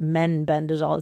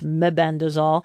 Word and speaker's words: mebendazole: Many people say that incorrectly. It's MEB, not menbendazole. It's mebendazole. And that's the human prescription, whereas the animal fenbendazole is available in an over-counter product.